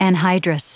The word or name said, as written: anhydrous